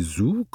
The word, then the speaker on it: Zoo: Le Zoo Fitness, prononcé Zoo comme pour le zoo anglais,